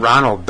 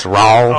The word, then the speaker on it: Roald: It's not Ronald Dahl. It's Raul.